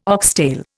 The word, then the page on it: oxtail: Indian